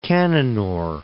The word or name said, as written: Cannanore